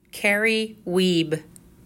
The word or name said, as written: Carrie Wiebe